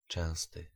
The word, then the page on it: fréquent: [fʁe.kɑ̃]; France